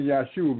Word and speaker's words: Yahshua: And I keep saying Yahshua because I want you all to know that the proper way to pronounce the name of the Messiah. Because you see, there were no J's in the Hebrew language. So that's a Y in front of his name.